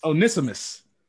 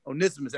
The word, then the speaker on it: Onesimus: Onesimus. Is that how you had? – Onesimus. – Onesimus. That's how you say it? I'm sorry. – Yeah.